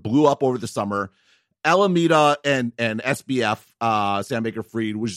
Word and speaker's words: Alameda: Number one, I kept saying Alameda. It's actually Alameda.